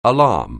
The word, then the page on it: alarm: English